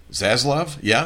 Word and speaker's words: Zaslav: And, you know, one was that David Zaslav, who came in as the head CEO of Discovery, am I pronouncing his name correctly? – Zaslav? Yeah.